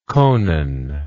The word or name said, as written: conan